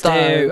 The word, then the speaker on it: Plaistow: How do we say this again? Plaistow. – No. – Plaistow. Plaistow. Oh my, that sounds much nicer.